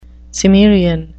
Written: Cimmerian

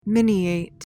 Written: miniate

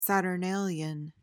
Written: saturnalian

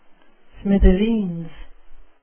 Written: smithereens

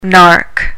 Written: nark

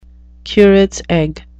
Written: curate's egg

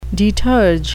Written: deterge

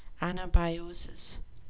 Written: anabiosis

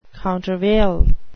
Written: countervail

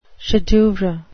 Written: chef-d'oeuvre

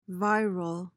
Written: viral

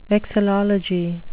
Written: vexillology

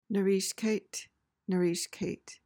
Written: narrischkeit

